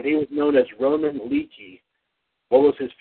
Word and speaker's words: Leakey: John, when he went by the name Roman Leakey, I guess that's how you pronounce it, I guess. – Yeah, it's Leakey.